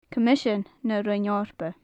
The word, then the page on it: coimisean na roinn eorpa: gaelic